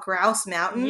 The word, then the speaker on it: Grouse Mountain: And we hiked on, it's called Grouse Mountain.